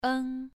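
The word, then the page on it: eng: tone 2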